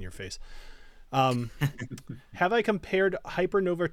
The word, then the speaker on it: Hypernova: I just totally pronounced that weird. Hypernova. So very New England of me.